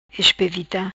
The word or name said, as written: Espevita